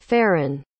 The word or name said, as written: farryn